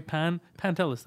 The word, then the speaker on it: Pantelis: I go, you know, like the phone company, Telus? Say that. So just pronounce Pantelis. The same way. Pantelis.